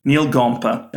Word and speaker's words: Neal Gompa: Neal Gompa.